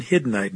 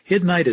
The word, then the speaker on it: Hiddenite: You've been listening to a message by Dr. Sam Vellini, senior pastor of the Freedom Baptist Church in Hiddenite, North Carolina.